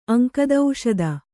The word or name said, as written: ಅಂಕದೌಷದ